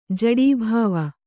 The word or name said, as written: ಜಡೀಭಾವ